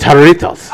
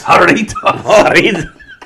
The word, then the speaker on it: Jarritos: It's Jarritos. – Jarritos.